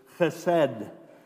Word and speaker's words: chesed: Now, in the Old Testament Hebrew, the original word for steadfast love is chesed. And it's one of those words in Hebrew where, to say it properly, you need to spit a little bit.